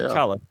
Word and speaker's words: T'Challa: T'Challa.